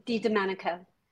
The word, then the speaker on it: DiDomenico: DiDomenico.